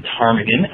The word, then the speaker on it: ptarmigan: It's spelled P-T-A-R-M-I-G-A-N. So you don't pronounce the P in ptarmigan, kind of like you don't pronounce the P in pterodactyl.